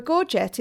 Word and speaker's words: gorget: So one thing you mentioned earlier was the gorgets. Now, I thought this was gorge, and because that sounds more romantic than gorget, but I listened to a pronunciation tutorial,